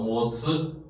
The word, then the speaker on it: 物: 这里的物呢念成模词，模词。 OK，再下面一个萨科模词也是念模词。